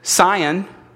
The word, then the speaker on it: scion: And the part that is being engrafted into the rootstock is the, I believe you pronounce it, scion. What is it? – Scion. – What did I say? – Scion. – Emphasize the O, sorry. I'm from Indiana, that's just how we talk.